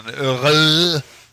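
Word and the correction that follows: Røde: Eller hur det uttalas. Oklart. Ja. – Röde. – Röde. Ja.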